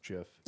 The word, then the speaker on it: GIF: The original founders of the invention of that whole file, like, extension said it's pronounced GIF.